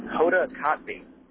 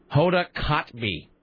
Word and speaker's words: Hoda Kotb: It's Hoda Kotb. – Hoda Kotb. – Kotb. You actually just say Kot and then you pronounce the V.